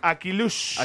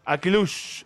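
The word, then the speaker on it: Akliouche: Akilush. – ¿Akilush se pronuncia? – Akilush, al minuto 63.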